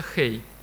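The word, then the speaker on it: Хей (הא): Вот произносится «Хей».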